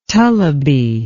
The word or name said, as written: tullibee